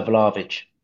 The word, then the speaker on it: Vlahovic: Yeah, Vlahovic.